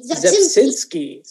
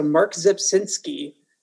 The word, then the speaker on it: Rzepczynski: I'm going to attempt it and say Zepczynski. – So the R is silent. Okay, so Mark Zepczynski, otherwise known as Scrabble because of his name spelling.